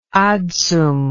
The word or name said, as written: adsum